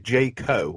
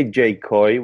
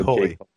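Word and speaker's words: J Coy: Is it J. Co. or J. Col? – You say J. Coy, which is short for... – Coy.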